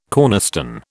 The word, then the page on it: cornerstone: British English